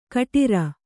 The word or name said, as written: ಘುಟುರು